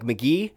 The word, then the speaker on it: McGee: and I'm like McGee, and they're like.